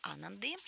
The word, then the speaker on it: Ананды: Следующая сказка у нас называется «Счастье Ананды». Опять ударение было прямо в книжке на Ананды.